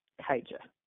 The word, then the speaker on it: Kaija: So when I meet people and I pronounce my name, I always say it's silent A, long I, hard J. Kaija.